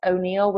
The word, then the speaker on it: O'Neill: So it's T-O-N-I-A and then Jackson, J-A-C-K-S-O-N. Last name is O'Neill, which is O-N-E-I-L-L.